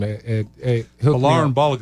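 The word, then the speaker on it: Folarin Balogun: Valarin Balogun.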